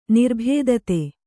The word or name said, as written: ನಿರ್ಬಾಧಿತ